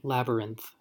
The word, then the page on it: labyrinth: American English